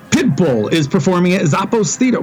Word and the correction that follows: Zappos: Wow, what is happening with my inflections? Zappos. I put the wrong emphasis on the wrong syllable.